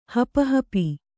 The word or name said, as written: ಹಪಹಪಿ